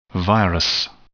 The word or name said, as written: virus